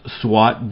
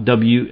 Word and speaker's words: SWOT: Conducting a SWOT audit, pronounced SWOT, SWAT, can be a very valuable tool in assessing your circumstances and making a strategic plan of action.